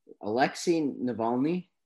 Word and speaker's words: Alexei Navalny: Alexei Navalny.